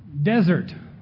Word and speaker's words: desert: So that first word on the top there, the top picture, desert. Two syllables, emphasis on the des and not on the ert.